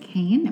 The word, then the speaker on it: Caen: University Hospital of Caen. Am I saying that right? – Yes, that's fine.